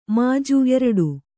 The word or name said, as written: ಮುಜ್ಜು